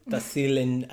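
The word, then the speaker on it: Tassili: Tahsil in Ajir. – Yeah. – I fucked up the pronunciation. How do you say it? – Tahsili, Tahsili.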